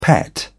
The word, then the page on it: pet: British English